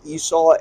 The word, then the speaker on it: Esau: Yeah, Esau.